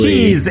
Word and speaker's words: Cheethes: Cheethes is spelled C-H-E-E-T-H-E-S. There is no V. Sylvie pronounces it with a V because she's Sylvie. – And Fernando wasn't there, so he wasn't there mentally. – Nope, it's Cheese, and one day he will meet Mac.